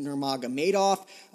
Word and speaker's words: Nurmagomedov: Gaethje's going to take on Khabib Nurmagomedov. I cannot believe I pronounced that right. I almost never get that name right, but he's going to face Khabib versus Gaethje in the future as well for the lightweight championship.